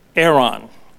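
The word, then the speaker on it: Aaron (ארון): In the Hebrew, the word ark in the Ark of the Covenant is the word Aaron, and that's spelled A-R-O-N, which is Strong's number 727, and it means ark, chest, or coffin.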